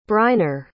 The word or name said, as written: briner